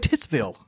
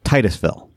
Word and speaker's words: Titusville: I always want to say Titsville. – Yeah, I know. Titusville.